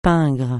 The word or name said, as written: pingre